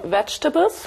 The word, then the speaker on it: vegetables: I've brought some vegetables. Ja, Vorsicht mit der Aussprache. Äh, das zweite E ist stumm, also mhm. Vegetables. – Lies okay. nochmal bitte. I've brought some vegetables.